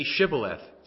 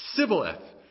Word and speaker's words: Shibboleth: Now, Shibboleth meant a stream or a flood, but they couldn't pronounce it. Say Shibboleth. Sibboleth. No, say Shibboleth. Sibboleth. You're dead. You know what I'm saying? These guys couldn't say Shibboleth.